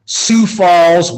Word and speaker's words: Sioux Falls: He's the head girls basketball coach at Sioux Falls Washington High School.